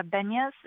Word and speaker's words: Benyus: Benyus, and you can pronounce it correctly for the listeners, but also about the Youth Design Challenge, because this isn't just for adults.